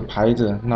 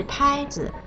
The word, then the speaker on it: 拍子: 这个羽毛球牌子难道不叫牌子吗？还是我说的不对？ – 哦，牌子，约翰，问题是出在了你的声调上啊。你想说的应该是拍子，拍是一声，而你说成了牌子，牌是二声，所以啊，应该是我要买羽毛球拍子，而不是我要买牌子。